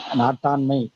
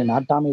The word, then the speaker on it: நாட்டாண்மை: அது உச்சரிக்கும் நாட்டாண்மை என்று நாம் சொன்னாலும் கூட அதில் இன் ஏன் வருகிறது நாட்டாமை தானே சரியாக இருக்கும் என்று அந்த திரைப்படம் வந்த பிறகு நாட்டாமை என்பதுதான் சரி நாட்டாண்மை என்கின்ற சரியான